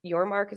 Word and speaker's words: Yormark: Yormark, is that? – Brett Yormark.